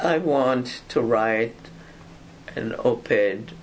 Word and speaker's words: op-ed: Op-ed? – Op-ed, yep. – Is that the way you pronounce it? – That's the way we pronounce it, op-ed.